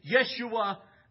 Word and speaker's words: Yeshua: they hanged Yeshua, the Hebrew pronunciation of Jesus, Yeshua of Nazareth.